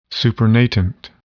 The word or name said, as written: supernatant